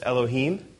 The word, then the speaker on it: Elohim: The one on the left is pronounced Elohim,